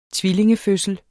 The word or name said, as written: tvillingefødsel